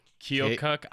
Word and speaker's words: Keokuk: Keokuk, Iowa.